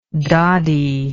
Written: doddy

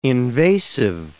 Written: invasive

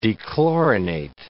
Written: dechlorinate

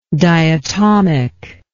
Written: diatomicity

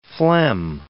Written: phlegm

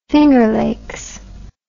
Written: Finger Lakes

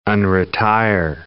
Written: unretire

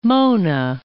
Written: Mona

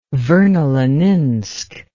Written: vernoleninsk

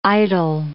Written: idol

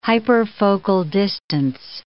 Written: hyperfocal distance

